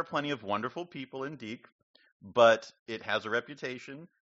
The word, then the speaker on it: Deke: Everybody at Yale knows what you think when you hear that they're in Deke, as they pronounce it on campus.